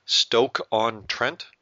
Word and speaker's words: Stoke-on-Trent: I'm probably going to say this wrong, but I'll try. Stoke-on-Trent. – Yep, spot on.